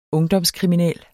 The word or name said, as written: ungdomskriminel